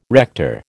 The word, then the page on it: rector: American English